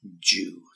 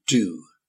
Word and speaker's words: due: Well, we're going to look at the word Jew. So first of all, just the spelling D-U-E, and pronunciation is almost with the J sound, like J-J, so Jew, okay? Jew, D-U-E, but sounds like J, Jew.